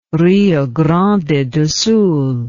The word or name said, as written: Rio Grande do Sul